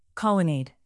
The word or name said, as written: colonnade